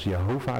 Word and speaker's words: Jehovah: Toen de mens en zijn vrouw, en ik ga meteen zeggen, ik ga Gods naam als Jehovah uitspreken. Sommige mensen zeggen Yahweh of Jeweh, dat maakt mij allemaal verder niet uit.